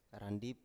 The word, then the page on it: randip: Biak